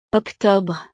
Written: octobre

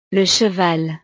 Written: le cheval

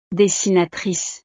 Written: dessinatrice

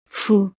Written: fou

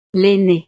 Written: l'ainee

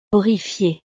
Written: horrifié

